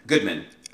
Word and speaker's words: Goodman: Bergdorf and Godman. Goodman. Okay. Bergdorf and Goodman.